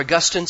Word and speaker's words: Augustine: Most of you have heard of Augustine, haven't you? Or Augustine, sometimes his name is pronounced that way.